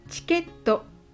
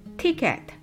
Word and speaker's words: ticket: ticket はチケットではなく ticket と発音してください。